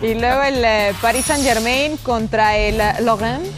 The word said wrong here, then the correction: Lorient: Lo Lorient. pronuncia bien a Lorient.